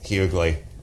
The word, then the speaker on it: Hughley: Hughley? – Hughley.